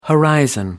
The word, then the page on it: horizon: American English